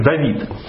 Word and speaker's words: Давид: Его можно прочесть как Дуд, можно прочесть как Дот, но вообще оно читается как Давид.